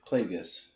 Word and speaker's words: Plagueis: Is it Plagueis or Plagueis? – I think it's hard G, Plagueis, I think.